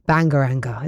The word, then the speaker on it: Bangaranga: It saw Bulgaria win the competition for the first time ever with their song Bangaranga. Is that right? Have I said it right, Freddie? – You have said it right.